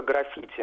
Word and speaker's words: граффити: Вот на портале Грамота.ру когда-то вот задавались вопросы, и были ответы, что вот только граффити нужно произносить. И когда-то в одном словаре, по-моему, это был большой толковый словарь Кузнецова, там приводилось ударение на первом слоге, и вопрос был в том, почему в разных словарях по-разному.